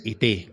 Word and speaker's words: ET: Es ET, con acento en la segunda E.